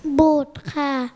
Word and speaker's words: บุตร: บุตรค่ะรอเรือไม่ออกเสียงค่ะ